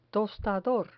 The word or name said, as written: tostador